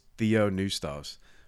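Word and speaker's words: theopneustos (θεόπνευστος): Now, I know many of your all's translations probably say all scripture is inspired, but in Greek, the word, and this is how you say it, is theopneustos, which is God breathed.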